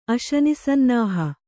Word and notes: ಅಶನಿಸನ್ನಾಹ: Kannada

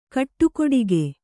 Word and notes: ಕಟುಗೊಡಗಿ: Kannada

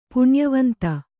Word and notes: ಪುಣ್ಯವಂತೆ: puṇyavante

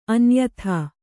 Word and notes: ಅನ್ಯಥಾ: Kannada

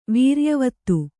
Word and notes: ವೀರ್ಯವತಿ: vīryavati

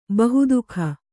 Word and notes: ಬಹೂದಕ: bahūdaka

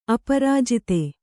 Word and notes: ಅಪರಾಜಿತ: Kannada

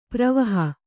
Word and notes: ಪರಿವಹ: parivaha

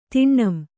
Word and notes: ತಿಣ್ಣಂ: tiṇṇam

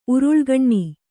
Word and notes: ಉರುಳುಗಣ್ಣಿ: uruḷugaṇṇi

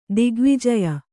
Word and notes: ದಿಗ್ವಿಜಯ: digvijaya

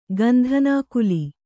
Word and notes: ಗಂಧನಾಕುಲಿ: gandhanākuli

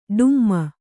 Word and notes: ಡುಮ್ಮು: ḍummu

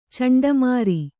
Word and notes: ಚಂಡಮಾರಿ: Kannada

